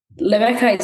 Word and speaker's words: Lebeka: Lebeka Leka," and Lebeka is my nickname. Just how you pronounce Rebecca in my language, Kirundi, and Leka means to let go.